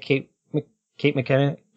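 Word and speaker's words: Kate McKinnon: So we'll see how people feel about this, but I didn't really like Kate McKenna.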